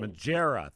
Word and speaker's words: Magiera: Lauren Maggiera standing by at Lauren, M-A-G-I-E-R-A. Let's start there, Lauren. Am I pronouncing your last name right? – You are. Maggiera. Good morning. – Maggiera.